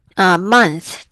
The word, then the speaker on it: month: OK，然后这里有 month 这个字的发音要注意哦，n 要让它鼻腔共鸣要跑上去，不然听起来像 mouth 的话就变成嘴巴了嘛。